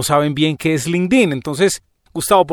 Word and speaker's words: LinkedIn: Bueno, pues LinkedIn es la herramienta que, bueno, realmente voy a decir, tú lo estás pronunciando correctamente, LinkedIn. Yo creo que en todos los países de hispanohablantes le decimos LinkedIn, pues por eso estoy diciendo LinkedIn.